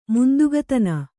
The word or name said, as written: ಮುಂದುಗತನ